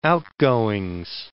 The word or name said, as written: outgoings